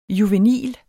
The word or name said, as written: juvenil